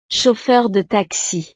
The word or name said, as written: chauffeur de taxi